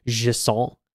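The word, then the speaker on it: Jusant: I played a Jisson. That's how you say it. It's French.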